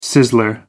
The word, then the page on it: sizzler: American English